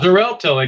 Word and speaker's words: Xarelto: Xarelto, exactly. – The one that sounds like a Z, but it's spelled with an X.